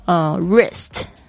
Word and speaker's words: wrist: 还有什么字，比如说像是 wrist 手腕这个字呢，它也是一样 WRIST wrist，它也是 W 不发音，因为不好发、啊、但是大家就不要忘记 W。